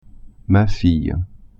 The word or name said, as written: fille